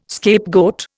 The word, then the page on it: scapegoat: Indian